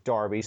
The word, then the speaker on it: Derby: Yes, I'm using the English pronunciation of Derby.